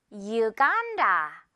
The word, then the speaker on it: Uganda: 영어상 U 발음 나요. 그래서 Uganda. U. 기억해야겠죠, 여러분? Uganda. 아하. 예문 볼까요? 그녀는 우간다에 머물고 있어. She's staying in 어디? Uganda. 그렇죠. 우라고 하면 완전히 틀린 발음이니까요.